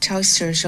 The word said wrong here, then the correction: Taoiseach: Okay, some pronunciation problems, but this is how the Taoiseach was introduced to the UN.